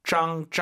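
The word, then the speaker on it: 脏: 짱. ZANG이고요. 짱, 짱 세게 발음하시면 ZH 같아요. 선생님처럼 하세요. 짱 들어볼게요. – 짱. – 이렇게 약간 한글로 표기 안 되는 지읒이에요. 짱 이렇게 발음하시면 됩니다.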